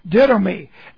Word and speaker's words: didomi: And this is D-I-D long O-M-I, didomi.